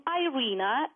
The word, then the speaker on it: Irina: So you can take a quiz, and it's a free gift for you if you go to Free Gift from Irina, and it's spelled as I-R-I-N-A,